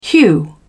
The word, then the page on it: hew: British English